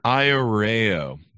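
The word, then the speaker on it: Ayoreo: Welcome to the show, and did I butcher the Arroyo people? – Thank you so much for having me, Chris. Yes, you butchered that. – I'm sorry. – But it's all right. – How do you pronounce it? – Iareo.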